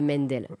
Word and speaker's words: Mendel: Mandel ou Mendel. La prononciation exacte, je crois que nous, on disait Mendel.